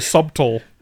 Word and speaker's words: subtle: I was like, I hope he doesn't think it's like SUDDLE or something. – SUDDLE? – No one says like, it's very subtle. You know, like, it's very subtle. – The word just is it's pronounced completely differently than it's spelled.